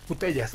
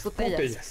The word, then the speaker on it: Putellas: Alexia Putelas o Putellas, no sé cómo se pronuncia eso. – Putellas. – Putellas. Sí.